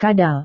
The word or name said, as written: kadal